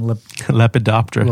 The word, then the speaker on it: Lepidoptera: Lepidoptera. – Lepidoptera. How do you spell it? – L-E. – Did it autocorrect it when you typed it? – L-E-P-I-D-O-P-T-E-R-A. Lepidoptera.